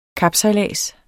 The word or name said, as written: kapsejlads